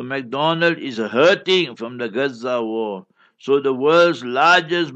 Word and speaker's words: Gaza: You know how we pronounce it, Gaza.